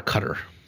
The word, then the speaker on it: Qatar: the, actual pronunciation seems to be up in the air, but according to a 2010 report by NPR, Mike Pesca, the pronunciation that the government of Qatar gave him was that it should sound somewhat like guitar, like Qatar, yeah. – with the emphasis on the cut and the "tar."